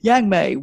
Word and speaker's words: Yang-May: You can find it by searching my name, Yang Mei Uy, and I'll spell that for you. Yang Mei, YANG-MAY, Uy, OOI.